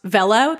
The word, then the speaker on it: VLLO: One is called, I think it's pronounced Vello. That's how I say it. V-L-L-O, capital V-L-L-O. Vello or InShot, I-N-S-H-O-T.